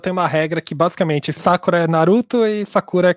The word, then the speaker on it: Sakura: E não como a gente aprendeu no Sakura Card Captors. Então, pra mim, eu sempre falei Sakura, mas é Sakura mesmo, né? Meio que a sílaba tônica é no primeiro.